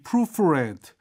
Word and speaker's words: proofread: 여러분, 이 proofread, 이 단어를 proofread라고 읽으면 안 돼요. 왜냐하면 저 뒤가 didn't you잖아요. 이게 과거예요. 그래서 이 read, read, read 동사 변형 아시죠? 여기도 proofread, proofread, proofread 스펠링은 똑같지만 이건 이제 과거형으로 읽을 줄 아셔야 됩니다.